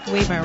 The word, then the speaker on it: Weimer: Weimer rhymes with Weiner.